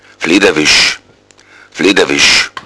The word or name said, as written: fleederwisch